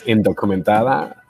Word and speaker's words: Indocumentada: It's Indocumentada,